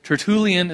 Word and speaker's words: Tertullian: And I put, as far as a quote goes, on the back of your notes today, a quote by a church father, Tertullian is how you say his name.